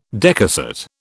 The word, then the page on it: decussate: British English